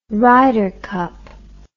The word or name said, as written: ryder cup